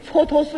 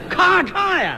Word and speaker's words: 蹉跎: 这就是前些日子我看那个电视连续剧《蹉跎岁月》，我那个题目念错了。 – 哦，蹉跎俩字儿不认识？ – 我不认识。 – 你念成什么了？ – 我念成咔嚓岁月。 – 哎，咔嚓呀！